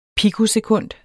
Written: pikosekund

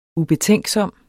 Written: ubetænksom

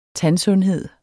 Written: tandsundhed

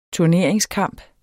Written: turneringskamp

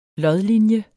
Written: lodlinje

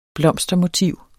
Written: blomstermotiv

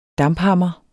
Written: damphammer

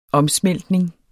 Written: omsmeltning

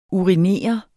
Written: urinere

